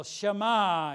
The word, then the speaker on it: Shema: The word in Deuteronomy 6:4 translated, Hear, O Israel, Shema Yisrael. So the Hebrew word from Deuteronomy 6:4, if you want to spell it in English, S-H-E-M-A, Shema.